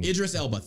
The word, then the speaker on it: Idris Elba: Yeah, but I mean, Idris Elba.